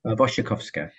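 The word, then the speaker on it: Wasikowska: Wojciechowska.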